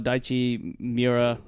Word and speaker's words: Daichi Miura: For those who don't know, Daichi Miura is, I don't know if I'm saying his name right. – Yeah, you're saying it right.